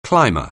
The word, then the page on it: climber: anglais